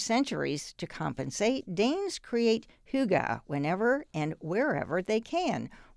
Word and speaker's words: hygge: Indeed, the most fashionable Scandinavian import this season is the intangible, the Danish concept of hygge, sometimes pronounced hygge.